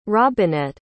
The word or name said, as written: robinette